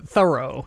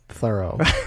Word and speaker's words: Thoreau: So when Nathaniel Hawthorne first met him, Thoreau, in 1842, he wrote in his journal, "Dined with Mr. Thoreau," and he spelled it T-H-O-R-O-W. So he was spelling it phonetically.